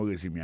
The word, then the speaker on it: résumé: I see from your resume, moi, ouais, non, c'est vrai, non, curriculum vitae, ou, regardez qui j'ai prononcé mon résumé, assis, pour mon résumé, accent tombé sur le premier syllabe là.